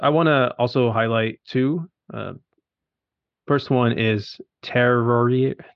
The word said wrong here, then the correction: Terroir: I don't even know how to say it. Terroir.